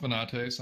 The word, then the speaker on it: Venates: Well, my name is pronounced Venates. I know a couple of people have had trouble with that.